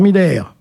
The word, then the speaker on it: Miller: Gérard Miller, gros liqueur, à prononcer à l'allemande, Jean-Luc Morandini, animateur, Patrick Poivre d'Arvor, journaliste, Tariq Ramadan, islamologue.